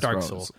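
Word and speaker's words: Dark Sol: I assume it's pronounced Dark Soul. It's yeah. Dark S-O-L. – Yeah, it's Dark Soul.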